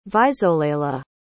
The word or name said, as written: visolela